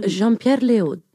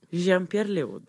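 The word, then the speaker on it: Léaud: Jean-Pierre Leaud. – Jean-Pierre Leaud.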